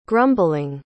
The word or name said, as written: grumbling